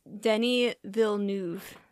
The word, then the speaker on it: Denis Villeneuve: The pronunciation I read was Denis Villeneuve.